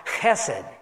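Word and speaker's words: chesed (חסד): It's pronounced chesed. It means loyal love.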